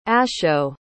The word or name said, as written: asho